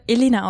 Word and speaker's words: Elena: Ich werde Elena ausgesprochen. Also ähnlich wie Elena Miras.